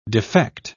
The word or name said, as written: defect